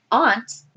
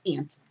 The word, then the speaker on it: aunt: Speaking of aunt, Michelle Stafford pronounced it correctly, and this new Nina doesn't. She says aunt. And the old Nina said aunt, because it's A-U-N-T, not the little bug.